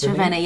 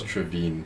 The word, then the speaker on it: Trivene: Yes, Trivene. Yes, you said it right. – Is that like Fenty Beauty or something? – First I thought it was Trevine. I'm so sorry. – No, it's not Trevine.